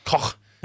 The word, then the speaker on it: Koch: Koch. Is that how you pronounce it? Koch.